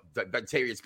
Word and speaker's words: Vontavious: And what about our man, Montavious? Say his name. I can't. It's something, but Vontavious Porter.